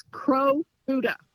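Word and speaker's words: Kurobuta: They say it like it's Crow Buddha.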